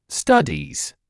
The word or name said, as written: studies